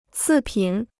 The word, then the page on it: 次品: cì pǐn